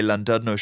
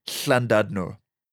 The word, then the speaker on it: Llandudno: For instance, llanelli, or the way Landadno should be pronounced, which is Llandudno.